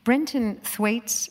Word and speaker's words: Brenton Thwaites: Brenton Thwaites, am I pronouncing it right? – Yes, you are.